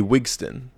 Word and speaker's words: Wigston: And we meet Wingston? – Well, we meet W, a.k.a. Wigston.